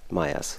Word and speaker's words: Meyers: Nicht, Seth ja. Meyers, so wird ausgesprochen.